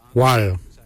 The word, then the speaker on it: waly: Joop is spelled D-J-O-B. Joop. Joop is your kidney. Lungs, we say wal. Wal is your lungs. Wal is spelled W-A-L-Y.